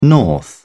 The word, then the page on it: north: British English